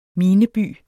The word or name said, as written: mineby